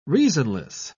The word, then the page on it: reasonless: British English